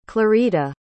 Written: clarita

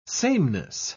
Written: sameness

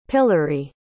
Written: pilary

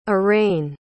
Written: arayne

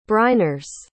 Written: briners

